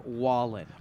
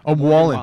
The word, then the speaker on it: Wallen: It's actually Morgan Wallen. – Wallen, see? – It's okay.